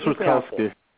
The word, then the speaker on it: Rutkowski: Chris Rutkowski.